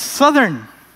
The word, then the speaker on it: Southern: How do you say it? South. Once you add E R N, it's Southern.